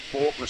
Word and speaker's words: Falconer: Well, we're at 191, well, it's pronounced Faulkner Street. It's actually spelt Falconer Street in Guyra.